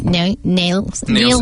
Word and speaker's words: Nielsen: I always want to pronounce it differently. I don't know why. It's because it's got an I in it. And I think Nelson, but it's not. It's Nielsen. – Nielsen.